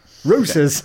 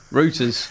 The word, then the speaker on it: Reuters: How do you say it? – Reuters. – Reuters. – Yeah. Rooters. – Okay. Rooters.